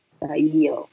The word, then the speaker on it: Thayil: Jit bacanya Jit Tayilnya T H A Y I L. Tayil.